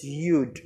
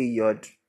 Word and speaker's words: Yud: And the tenth is Yud. Some say Yod. You are also excused.